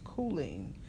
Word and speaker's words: choline: But, you know, but the report also identified nutrients such as, I think it's pronounced choline, but it's spelled C-H-O-L-I-N-E.